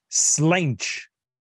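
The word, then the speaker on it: sláinte: Il dit ça se prononce slinch.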